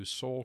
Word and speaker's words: Seoul: S-E-O-U-L, as in Seoul, Korea.